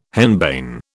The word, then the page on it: henbane: British English